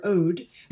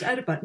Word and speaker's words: Oedipa: and it's spelled O-E-D. So unless we think he's calling her Ode, he is, I believe it's pronounced Oedipa. Now, I actually want to come back to O-E-D because that's a very Pynchonesque joke and there's a reason why her name is Ode.